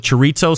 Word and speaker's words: chorizo: That's how you say it properly. – It's C-H-O-R-I-Z-O. Not C-H-R-I-Z-O. T-Z-O. It's not chorizo.